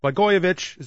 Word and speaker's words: Blagojevich: Blagojevich, is that the proper pronunciation? – It is.